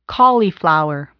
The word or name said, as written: cauliflower